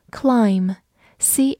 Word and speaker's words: climb: 我们知道，在这个单词当中，末尾的 b 放在 m 后面不发音。和它比较类似的例子还有 climb，c l i m b，同样的 b 不发音，它是一个动词或者名词，表示爬或者攀登。